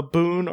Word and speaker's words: Bune: Uh, the 26th uh, demon, Bune or bim, a Bune, and that's that's with the silent e on it. Not like a BOO mm.